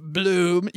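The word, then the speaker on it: Blooom: To find out more with Bloom, you can simply connect your existing 401k in a few easy steps and sit back and relax while Bloom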